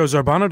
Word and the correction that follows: Zambrano: doesn't respond to my... I mean, I'm sorry. – Joe Zambrano. – I hate to mispronounce the boss's name.